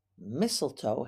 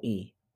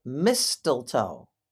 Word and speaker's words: mistletoe: Mistletoe has a silent T in the middle. It's mistletoe, but we do not pronounce that first T. People sometimes pronounce it mistletoe, but that is not how it is pronounced. It is mistletoe.